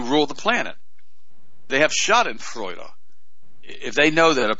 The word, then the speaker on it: Schadenfreude: Schadenfreude. And that's S-C-H-A-D-E-N-F-R-E-U-D-E, if you want me to spell that for you.